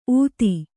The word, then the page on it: ಊತಿ: ūti